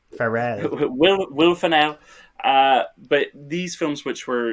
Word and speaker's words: Ferrell: But it was in that wave of these Judd Apatow type comedies with who I realized I kept saying Will Ferrell, like I'm saying Emerald Fennell, but it's Will Ferrell.